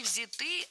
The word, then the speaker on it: взяты: Не взяты, а взяты. Ударение в этом слове сохраняется на первом слоге. Он взят, оно взято, они взяты. И только лишь в женском роде ударение переносим на окончание.